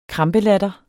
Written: krampelatter

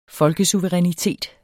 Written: folkesuverænitet

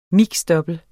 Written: double

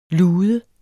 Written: lude

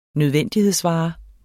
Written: nødvendighedsvare

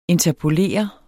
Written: INTERPOLERE

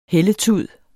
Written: hældetud

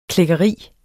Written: klækkeri

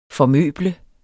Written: formøble

